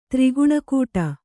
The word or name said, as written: ತ್ರಿಗುಣಕೂಟ